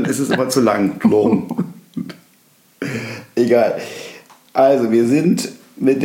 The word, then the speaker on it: Toulon: Toulon.